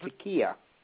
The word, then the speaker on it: taqiyya: It's spelled T-A-Q-I-Y-Y-A. – That's right, Takiya.